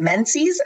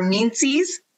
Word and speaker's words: menses: Was it menses or meanses? Say it properly. Menses? Okay.